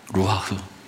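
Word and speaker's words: 루하흐 (רוּחַ): 히브리어의 발음으로는 루하흐라고 이렇게 발음합니다. 루하흐.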